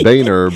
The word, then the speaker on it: Boehner: Were they Biden and Boner? – Boner and Biden, right. – Is that his name, Boner? – Famous vaudeville team. It's Boehner, but it's spelled sorry. Boner. – Yeah, yeah, Boehner. I'm sorry.